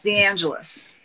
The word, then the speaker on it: DeAngelis: DeAngelis.